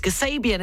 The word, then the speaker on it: Kasabian: And I must apologise to Bradley. It's Kasabian, isn't it? The Butcher Blues, for my pronunciation earlier on. It was absolutely shocking.